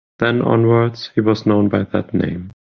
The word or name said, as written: onwards